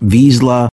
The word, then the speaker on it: Vizsla: That's V-I-Z-S-L-A, pronounced Vizsla or Vizsla, depending on who you talk to.